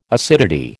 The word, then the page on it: acidity: American English